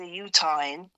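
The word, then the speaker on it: Utahn: He's a Utahan. That's how they say it.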